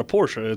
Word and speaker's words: Porsche: And it's pronounced Porsche, actually. But yeah, I know.